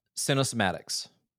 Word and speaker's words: Cinesomatics: Um, so you, one of your like uh, a core approach, and I'm curious, uh, cinesomatics. I think I said that right. Is that yes. – right? – Okay. Cinesomatics. Yep. Cinesomatics.